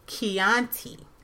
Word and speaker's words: Chianti: B-R-U-T is pronounced as Brut. Chianti is actually Chianti with the K.